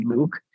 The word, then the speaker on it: Luc: The way my name is spelled, it is French. It's spelled O-U-C, Luke.